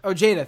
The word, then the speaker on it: Ojeda: Ojeda? Ojeda, thank you. The guy from West Virginia who already dropped out. Also, Avenetti already dropped out, but this was her tweet. Ojeda